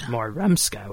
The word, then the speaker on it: Morumska: What we'd like his name pronounced as Morumska, which I think I just did.